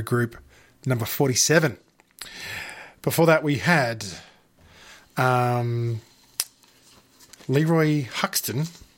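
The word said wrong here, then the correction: Hutson: Hudson, if I can say it correctly.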